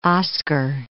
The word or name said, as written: Oscar II